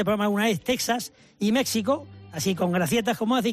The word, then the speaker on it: México: Vale, porque la X que escribe, con la que se escribe México y Texas, la X con la que se escribe, se pronuncia J, que es la grafía española del siglo XVI, que era con valor gutural, o sea, de J. Por lo tanto, nosotros debemos escribir con X y pronunciar México, Oaxaca y Texas.